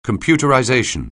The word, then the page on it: computerization: anglais